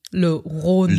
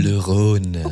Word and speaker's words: Le Rhône: How to pronounce. Le Rhône. – Le Rhône.